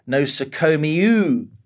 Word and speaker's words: nosokomiou: It's pronounced nosokomiou, like from the Greek word for hospital, from o sole mio.